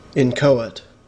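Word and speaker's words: inchoate: Incoate. Incoate. – No H in there.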